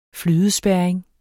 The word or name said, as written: flydespærring